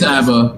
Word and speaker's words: ABBA: Blige, Easy on Me by Adele, and Don't Shut Me Down by ABBA. I don't know if that's pronounced ABBA or... – It's ABBA. – It's ABBA, yeah.